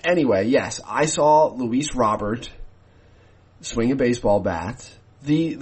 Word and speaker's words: Robert: His last name is spelt like Robert and is pronounced actually more similar to Robert than one might expect of a person who was born and raised in Cuba.